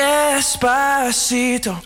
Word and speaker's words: Despacito: Despacito.